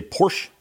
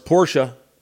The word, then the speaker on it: Porsche: And that's one of those words that everyone mispronounces. They say Porsche. And, of course, Porsche is a family name from Germany.